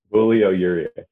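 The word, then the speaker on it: Julio Urías: Am I saying that right? I don't do a lot of scouting reports on Dodgers baseball throughout the year, but Julio Urias. You got it.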